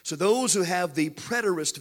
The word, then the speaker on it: preterist: The third view of Revelation is, I don't know if I'm going to pronounce this right, but it's the preterist view. It is P-R-E-T-E-R-I-S-T, the preterist view.